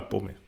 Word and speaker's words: Bomi: Aber bei Bomi bin ich mir sehr sicher. – Bomi ist klar, Bomi.